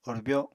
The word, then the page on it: orbyo: Apatani